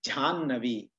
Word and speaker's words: ಜಾಹ್ನವಿ: ಇದು ಇದರಲ್ಲಿ ಜಾಹ್ನವಿ ಅನ್ನೋದು ಆ ಹ ಅಂತ ಬರ್ತದೆ ಇದು ಕಠಿಣ ಇದೆ ಶಬ್ದ ಉಚ್ಚಾರ ಮಾಡ್ಲಿಕ್ಕೆ ಒಪ್ತೀನಿ ಅದನ್ನ ಜಾಹ್ನವಿ ಅಂತ ಇರೋದನ್ನ ಆ ಹ ಮುಂದೆ ಇದೆ ಅದನ್ನ ಜಾಕೆ ಹಚ್ಚಿ ಝಾ ಅಂತ ಮಾಡಿ ಆಗ್ತದ ಅದನ್ನ ಜಾಹ್ನವಿ